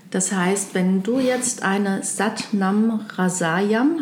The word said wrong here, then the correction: Rasayan: habe ich das richtig ausgesprochen? – Rasayan.